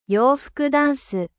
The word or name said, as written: armoire